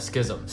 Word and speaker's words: Schism: Is it the Schism? – Schism.